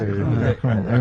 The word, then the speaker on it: Macron: Está bien la pronunciación, porque vos sos ducho en inglés. – Macron. – ¿No? – Macron. En francés.